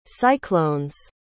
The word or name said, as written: cyclone's